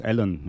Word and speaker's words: Elon: Also fremde Leute nennen ihn Elon und der sagt dann, das ist okay, aber die Betonung ist Elon.